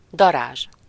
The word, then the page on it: wasp: /ˈwɒsp/; /ˈwɑsp/